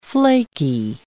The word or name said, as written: flakey